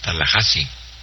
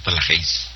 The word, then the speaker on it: Tallahassee: Florida, se pronuncia Tallahassee, no Tallahassee.